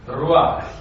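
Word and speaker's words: Ruach (רוח): The Hebrew word for spirit is ruach. – Now, please stand up. – Prosím, postav se. Hebrejské slovo pro ducha je. – And when you say the word, what say it? – Ruach.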